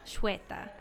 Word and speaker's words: Shveta: Shveta.